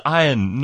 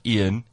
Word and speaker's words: Ian: His name is Ian, not Ian. Ian Ziering. – Even though it's spelled I-A-N.